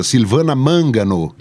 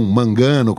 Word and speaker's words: Silvana Mangano: Silvana Mangano, e não Mangano, como a gente falava.